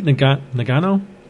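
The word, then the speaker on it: Ngannou: I was conflicted on this fight because I think Nagano, is that how you pronounce his last name? – Yes.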